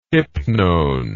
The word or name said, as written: hypnone